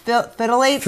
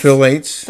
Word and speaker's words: phthalates: Phytrate? What is it? – Phthalates. – Phthalates.